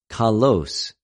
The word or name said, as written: καλως